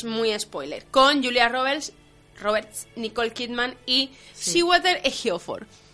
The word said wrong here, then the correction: Chiwetel: Chiwetel Ejiofor.